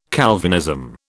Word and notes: Calvinism: British English

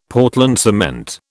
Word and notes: Portland cement: British English

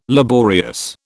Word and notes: laborious: British English

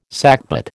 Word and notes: sackbut: American English

